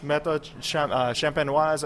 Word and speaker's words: méthode champenoise: And so it was a trade back and forth, and it's the method, méthode Champagne, I'm going to mispronounce it. Already I did it twice. Méthode champenoise à l'ancienne.